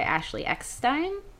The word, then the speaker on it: Ashley Eckstein: also something called Behind the Force, which was a live show at the Premier Theater hosted by Ashley Eckstein.